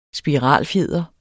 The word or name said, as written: spiralfjeder